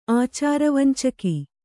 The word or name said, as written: ಆಚಾರವಂಚಕಿ